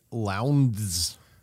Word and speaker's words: Lowndes: There was also a new generation of drivers, including Mark Skife and Craig Lowndes. – Rhymes with clowns.